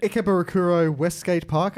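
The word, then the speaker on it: Ikebukuro West Gate Park: Ikebukuro Westgate Park.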